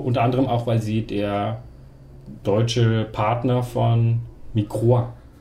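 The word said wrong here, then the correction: Microids: Microid und Astragon.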